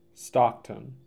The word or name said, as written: Stockton